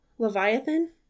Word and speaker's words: Leviathan: Leviathan. – Is that what that is? – I don't know. I'm Leviathan question mark. L-E-V-I-A. – L-E-V-I-A-T-H-A-N. Fitness. – Leviathan Fitness, yes. – Yeah. – Yeah. A Leviathan, that's the series is like name.